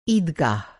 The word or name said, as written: ईदगाह